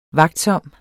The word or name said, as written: vagtsom